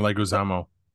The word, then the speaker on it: Leguizamo: It was, I'm probably butchering his name, but John Leguizamo. – John Leguizamo.